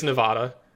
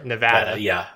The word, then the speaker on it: Nevada: I moved from Las Vegas, Nevada. I'm sorry, Nevada. – Sorry. There's no way you were going to make that mistake. You lived there. They're very particular about the pronunciation of Nevada.